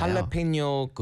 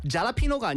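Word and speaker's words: jalapeño: 할라페뇨 그 페퍼도 페퍼 그렇죠. – 있잖아요. – 잘라피노가 아니고요. – 할라페뇨. – 할라페뇨. 할라페뇨라고 하죠.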